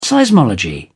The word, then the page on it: seismology: British English